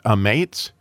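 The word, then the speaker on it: ametz (אמת): And so as we believe what Jesus says, we believe the truth, which in Hebrew, the word truth is pronounced ametz, which is an aleph, a mem, and a tav, which is the beginning of the Hebrew alphabet, the middle of the Hebrew alphabet, and the end of the Hebrew alphabet.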